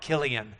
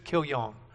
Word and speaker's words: Kilion: One is named Machlon, and the other one is, is uh, named, um, it's C, Kilion, Kilion in Hebrew, Kilion in Hebrew, but it's, when you read it, it reads like Kilian. But we'll stick with the Hebrew pronunciation. It's just Kilion.